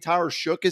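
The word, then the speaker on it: Tyler Shough: I think Tyler Shook is how you pronounce the name. – Yeah.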